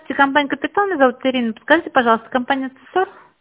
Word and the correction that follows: Цесар: Цесар, да. – Цесар, простите за ударение.